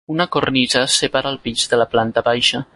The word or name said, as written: cornisa